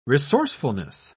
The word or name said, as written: resourcefulness